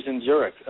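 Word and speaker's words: Dzinziruk: And it's nice to hear you pronounce Zinjuric right, as opposed to his two promoters who couldn't pronounce it at the press conference.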